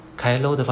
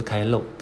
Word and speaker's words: 帰ろう: 开喽的发音比较正确，不是开漏，开漏的话是长音了。